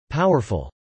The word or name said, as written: powerful